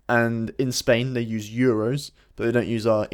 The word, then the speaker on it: euros: They don't say dos euros. They say... – Euros. – Euros. So they kind of pronounce it how it's spelt, which is a typical sort of Spanish thing to do. – Mm-hmm. – It's E-U-R, so it's E-U-R. Euro.